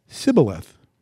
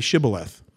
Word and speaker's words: Shibboleth: But he said Sibboleth, for he could not pronounce it correctly. Then they seized him and slew him at the fjords of Jordan. Thus there fell at that time 42,000 of Ephraim. 42,000 men. Now they weren't all killed because he couldn't say Shibboleth. But they had a dialect thing in their language. They couldn't pronounce that sh. It would come out s.